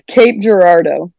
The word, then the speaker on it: Cape Girardeau: So it's it's Cape Girardeau. – Okay, Cape, Cape Girardeau. Girardeau.